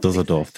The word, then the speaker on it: Düsseldorf: Düsseldorf.